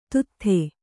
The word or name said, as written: ದದಾತಿ